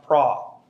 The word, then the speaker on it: pro: The word for before is P-R-O, but it's pronounced pra.